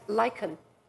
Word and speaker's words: lichen: She twined herself around him like lichen. Am I pronouncing it right? L-I-C-H-E-N. Yes.